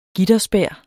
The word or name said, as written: gitterspær